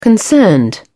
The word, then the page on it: concerned: British English